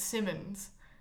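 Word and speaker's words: Simoens: Like, some people in my family feel very strongly about saying it Simmons, whereas my parents like to say it Simoons because that's the proper like Flemish way of saying it.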